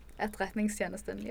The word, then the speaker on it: Etterretningstjenesten: Etterretningstjenesten, yeah.